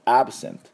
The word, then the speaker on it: absent: absent、 he is absent ということで、彼は休みですという意味になりますので、最後 t で止めましょう。